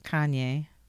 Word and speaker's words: Kanye: Kanye West. Is that how you pronounce his name? – Kanye.